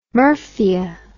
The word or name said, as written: murphia